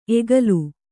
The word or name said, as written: ಎಕ್ಕಲ